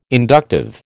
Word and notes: inductive: American English

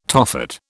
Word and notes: Tophet: British English

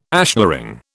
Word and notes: ashlaring: British English

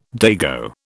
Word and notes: dago: British English

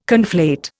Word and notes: conflate: Indian

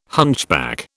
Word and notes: hunchback: British English

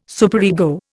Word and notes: superego: Indian

